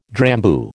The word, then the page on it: Drambuie: American English